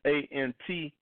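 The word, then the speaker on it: Ayentee: His name is A-N-T, but it's spelled A-Y-E-N-T-E-E, A-N-T. He was doing a song every week, trying to do it for a whole year.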